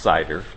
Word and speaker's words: CIDR: This is pronounced CIDR, like the Apple stuff.